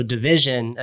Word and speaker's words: DVSN: I'm a huge Drake fan, but so Division, another artist. I was excited to see if KD had discovered yet. – For anybody out there, it's spelled D V S N, right? – And they're making some.